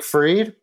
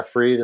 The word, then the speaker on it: Fried: It is Jake Freed, but it's spelled Fried, and I go by either, no matter what. But yeah, Freed is the official pronunciation.